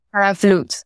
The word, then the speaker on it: Vloet: Vloet.